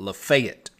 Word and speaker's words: Lafayette: Lafayette is the county, but here they pronounce it Lafayette.